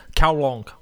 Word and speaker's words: Kowloon: So, it's in a place called Kowloon in Hong Kong, and the Cantonese pronunciation of that is Kowloong.